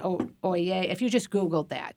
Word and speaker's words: Oyez: O-Y-E-Z. OYE. It's pronounced OYE.